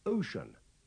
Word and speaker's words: ocean: Pay attention to the pronunciation of ocean. The C is pronounced with the sound at the beginning of shore.